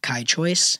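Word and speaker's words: Kai Choyce: You can find me on Twitter and Instagram at Kai Choice, and that's spelled just like my name, KAICHOYCE.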